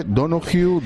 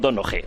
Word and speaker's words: Donohoe: Que depende, porque hay algunos que dicen Donohue porque él es irlandés. Entonces, como el apellido es irlandés, al parecer la H con la E sí que es verdad que habría que pronunciarla. Habría que decir algo así como Pascual Donohue, algo así como muy suave, ¿no?